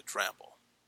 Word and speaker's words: Dramble: The Dramble, yeah. – The Dramble. Could you share with our audience a little bit about your background, education, and experience, and then we'll get into the Dramble.